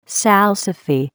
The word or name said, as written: salsify